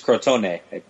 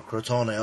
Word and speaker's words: Crotone: So even, actually, Victor has a piece on the Crotone game. Am I saying that right? – I think it's Crotone, I believe. – Oh, Crotone, okay, yeah.